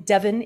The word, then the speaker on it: Devon: She lives in Devon, England, with her partner and two children. Did I pronounce Devon right? – You did.